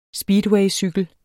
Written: speedwaycykel

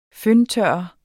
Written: føntørrer

